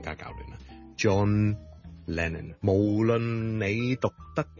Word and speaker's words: Lennon: 第一个字咧就系、是、人名嚟嘅，John Lennon，John Lennon，John Lennon，一个英国嘅好出名嘅歌手同作曲家啦，Lennon，L-E。